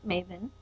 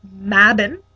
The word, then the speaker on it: Mabon: So it can be pronounced Maven. – Yes, that's how we like to pronounce it, Maven. – Or Mabin. – Or Mabun. – Or Mabon. – Or Maybin. – Or Maybone.